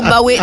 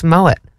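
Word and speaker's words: Moët: When it's like you actually realise that Moet is the correct way to say it, like poet. Whereas, that's the thing. People try to get too fancy with Moet, and it's Moet.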